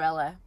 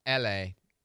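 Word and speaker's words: Elae: It's pronounced like the city. Again, another blank look on the face. – It's like the city. L-A. E-L-A-E. L-A. That's how you say it, L.A. Not Ellie, not Elay, not Eli. L.A. Like the C.A.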